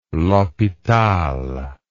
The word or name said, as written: l'hospital